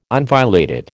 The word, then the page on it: unviolated: American English